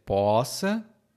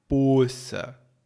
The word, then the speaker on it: poça: Aqui no Brasil, essa palavra tem duas pronúncias. Pode ser poça ou poça. Um O aberto ou fechado. E vai variar de acordo com a região.